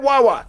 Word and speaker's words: Wawa: You said it right. You said Wawa.